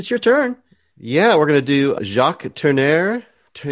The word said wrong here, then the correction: Tourneur: Is it Turner? Jacques Tourneur.